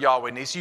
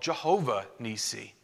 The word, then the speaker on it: Jehovah: Jehovah is an alternate way to say or to pronounce Yahweh. Ancient Hebrew had no vowels. It was all consonants. Yahweh was spelled YHWH. But in some languages, Y has a J sound and W has a V sound.